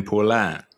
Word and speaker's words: Poulin: Eh, jag tycker ju att eh, Kevin Poulin han är ju en riktig... Perfekt uttal. Ja, så nära som möjligt.